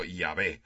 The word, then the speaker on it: Yahvé: No sabemos exactamente ni cómo se escribía, pero se pronuncia algo así como Yahvé.